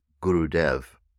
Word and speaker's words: Gurudev: This is the proper full name for the man who has become known as Gurudev, G-U-R-U-D-E-V-A. Gurudev is the proper pronunciation.